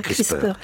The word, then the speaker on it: CRISPR: On va dire CRISPR.